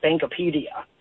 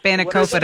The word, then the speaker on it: spanakopita: But the second time, we went to a Greek restaurant, and I ordered the Spankopedia. I don't even know how to say it. – Spanakopita.